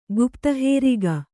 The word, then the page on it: ಗುಪ್ತಹೇರಿಗ: kannada